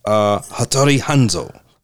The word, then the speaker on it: Hattori Hanzo: The Harizanzo knife. How you say the knife? How you say the name? Hattori Hanzo.